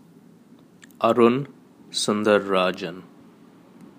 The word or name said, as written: Arun Sundararajan